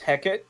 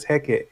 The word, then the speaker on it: Hecate: It turns out that in Shakespeare's time, they pronounce it as Hecate, and that's how I'm used to. – That's how I was used to, too. – But it is pronounced in some places as Hecate, yeah.